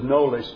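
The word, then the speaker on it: Knollys: The K is silent with Hansard Knowles.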